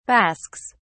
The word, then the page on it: basques: British English